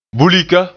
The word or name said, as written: bulika